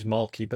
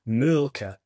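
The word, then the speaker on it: Mölkky: Most of the people we play against use Malky, but the Finns have a very unusual pronunciation, which, please forgive me, I'll try it. It's Mulka.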